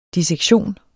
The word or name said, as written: dissektion